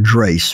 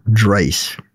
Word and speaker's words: Drace: Throughout all my life, anytime I've told people ask me what my name is, when I say the word Drace, for some reason, I guess I don't pronounce the D properly. I come up with Trace, I come up with Grace, everything but Drace.